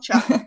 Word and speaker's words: chough: For example, the red-billed chough. Am I saying that right? Chough, cough, chough. – Chuff. – Chough, yeah.